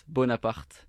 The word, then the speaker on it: Bonaparte: Okay, so it's Bonaparte. – Okay. – With a strong R.